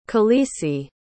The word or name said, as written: khaleesi